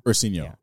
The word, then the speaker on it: Ursinho: So the Ursinho came about because how do you say it? Ursinho? – Ursinho, yeah. Which is Portuguese for little bear.